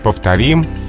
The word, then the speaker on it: повторим: Но от глаголе «повторить» пока ударение не перешло на корень слова, пока нормативно «повторишь» повторим, повторят, то есть на окончании.